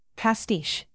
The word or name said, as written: pastiche